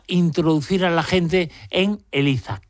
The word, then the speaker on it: ELISA: ELISA, y además está bien pronunciado con Z, aunque yo viva en Cádiz, no es que sea ELISA y yo le llame ELISA, que ya me lo han dicho por ahí, no, es ELISA con Z.